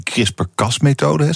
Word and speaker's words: CRISPR-Cas-methode: Dat is die CRISPR-Cas-methode, zeg ik het goed? Dat klopt, ja.